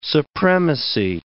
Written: supremacy

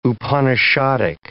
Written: Upanishadic

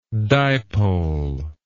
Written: dipolarize

